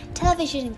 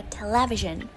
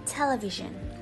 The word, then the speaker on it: television: rất nhiều người thường phát âm từ này với trọng âm rơi vào âm tiết thứ hai đó chính là television or television. Tuy nhiên thì trong thực tế, từ này khi phát âm thì trọng âm sẽ được nhấn vào âm tiết đầu tiên và sẽ được đọc là television hoặc là television.